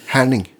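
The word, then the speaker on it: Herning: Her, hering, hering? Härning. Härning. härning.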